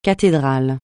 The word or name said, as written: cathédrale